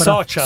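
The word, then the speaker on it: Soča: Legga bene, c'è la C con quella, con quella roba sopra. – Socia, Socia.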